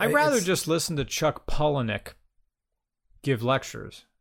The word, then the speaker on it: Palahniuk: Ah, the correct pronunciation of Chuck Palahniuk is Chuck Palahniuk.